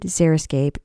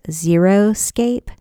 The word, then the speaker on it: xeriscape: Xeriscape is sometimes mispronounced as xeroscape, which to me sounds terrible.